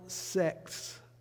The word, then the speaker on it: sects: In the time between the close of the Old Testament and the opening of the New Testament, there were several sects. I have to pronounce that carefully. S-E-C-T-S.